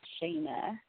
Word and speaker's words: Shenna: Okay, so it was funny because before the show, everybody always calling me Shayna, right? Because my name is spelled S-H-E-N-N-A. So everybody's like, Shayna, Shayna, Shayna. And I'm like, I don't know, Shayna. Like, I know it's spelled wrong, but it's been like that my whole life, but it's Shayna.